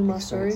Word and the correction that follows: Matsuri: Did I say it right? – Hana, Sushi Hana Matsuri. – Matsuri. I always just take the T out for some reason.